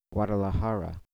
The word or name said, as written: Guadalajara